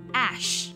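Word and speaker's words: Æ: 它读作 ash 和 ash 灰烬的这个单词呢发音是一样的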